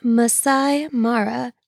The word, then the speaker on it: Masai Mara: I don't remember how to say it. Hold on. I feel like I have to look. Can I pause this? Pause. Okay, I'm back. It's Masai Mara.